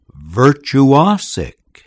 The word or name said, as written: virtuosic